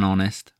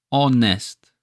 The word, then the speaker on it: honest: Even though honest, the adjective that describes the noun, starts with a H, we don't pronounce it. We say an honest man. When we pronounce it, we're not saying the H. You could get rid of the H. Honest. O-N-E-S-T. Honest. The H is silent here.